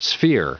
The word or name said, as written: sphere